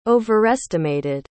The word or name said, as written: overestimated